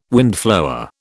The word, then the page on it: windflower: British English